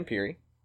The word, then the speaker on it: Pirri: Um, so the Rangers signed Brandon Pirri, and I pronounced that correctly. – Uh, you nailed it.